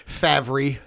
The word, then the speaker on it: Favre: So fuck Brett Favre. – Yeah. – Favre, sorry. Say your name right, bitch.